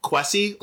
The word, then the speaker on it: Kwasi: Kwasi, like Jesse. – Okay. – Kwasi, like Jesse. Yeah.